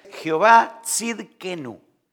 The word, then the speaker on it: Jehová Tzidkenu: Hoy quiero presentarle este nombre, no sé si lo han escuchado, es un nombre difícil de pronunciar, Jehová Tzidkenu.